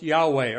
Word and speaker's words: Yahweh: By the way, you'll see in the notes later on, I use the spelling for, well, Tyndale says, let's pronounce it Jehovah. Later scholars have said it may have been more likely pronounced Yahweh or something like that.